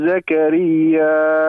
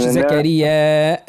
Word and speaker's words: زكريا: ذكر رحمة ربك عبده زكريا. قول. – ذكر رحمة ربك عبده زكريا. – لا مش زكريا. علاش هذيك؟ زكريا.